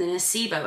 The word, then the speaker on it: nocebo: And one of the things that I saw that you spoke about was the nocebo effect. Am I saying it right? – Yeah.